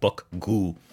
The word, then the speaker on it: Bukgu: And even the Hipster Baseball Podcast, Bullpen, could not figure out how to pronounce this, but it's Bukgu? South Korea, BUK-GU, Bukgu, South Korea.